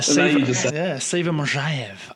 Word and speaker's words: Siva Majaev: Yeah, Siva Majaev. I put the Z in pleasure. That's how you say it.